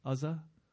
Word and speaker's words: Uzzah: Am I pronouncing that correctly? Uzzah? Uzzah? Yes.